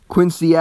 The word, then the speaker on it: Quincy: I figured that when I went to Quincy, Massachusetts, because we all kept saying Quincy, Quincy, Quincy, and they're like, no, that's not how you pronounce it in Boston, you pronounce it Quincy in Boston.